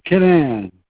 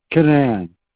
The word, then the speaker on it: Keenan: My name is Ray Canaan. – What is your name, sorry? – My name is Ray, K-E-E-N-A-N, pronounced Canaan.